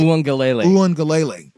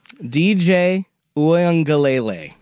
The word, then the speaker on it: Uiagalelei: But they got DJ Uiagalelei. Am I saying that right? – Uiagalelei. – Dang it. – Just say DJU. – No, I'm going to learn how to say his name. You know, yeah. One more time. – DJ Uiagalelei. – Uiagalelei.